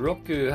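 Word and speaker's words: roku (六): and roku has a pretty strong R pronunciation, roku, roku, but yeah, when it's in a word like that, when it's not like the main sounding syllable, it's like changes its sound a little bit.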